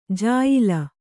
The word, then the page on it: ಜಾಯಿಲ: jāyila